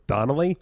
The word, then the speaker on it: Donnelly: They're like, Matt Dinelli. I was like, Donnelly?